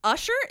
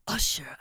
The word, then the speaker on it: Usher: Usher is also in town? – No, you said it wrong. – I'm sorry. Usher. – Thank you.